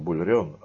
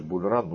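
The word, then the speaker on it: Bull Run: А я произношу по-старому Бульрен, а сейчас говорят Бульран, но извините, привык буду говорить так.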